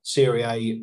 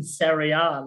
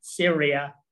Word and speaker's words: Serie A: I just remembered that I don't know if it was the Euros or the previous World Cup, Glenn Hoddle kept pronouncing Serie A like Syria.